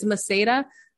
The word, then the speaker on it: Maceira: It's Maceira. It's Portuguese. – Portuguese. – So it doesn't follow like normal, you know, like pronunciation, but yeah.